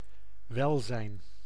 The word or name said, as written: hälsa